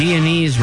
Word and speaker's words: Guyanese: And Guyanese rhymes with cheese.